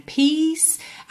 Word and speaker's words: peace: Atenção à pronúncia de peace. Nós temos P-E-A-C-E, mas nada de P-A-C-E, tá? Peace. Peace. Inclusive, o último E é mudo. E você termina esse C como se fosse um S.